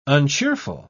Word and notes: uncheerful: British English

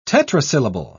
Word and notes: tetrasyllable: British English

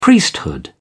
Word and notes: priesthood: British English

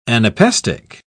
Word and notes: anapestic: British English

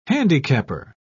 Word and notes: handicapper: British English

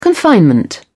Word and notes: confinement: British English

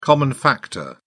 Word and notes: common factor: British English

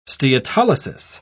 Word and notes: steatolysis: British English

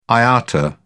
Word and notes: iata: British English

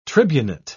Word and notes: tribunate: British English

